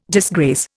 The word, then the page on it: disgrace: Indian